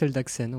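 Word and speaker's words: Eldagsen: Mais pourquoi est-ce qu'il l'a refusé Eh bien, car le candidat en question, Boris, désolé si j'écorce son nom, Eldaxen, Eldaxen, quelqu'un a une idée de comment on prononce ça Boris Eldaxen, ouais.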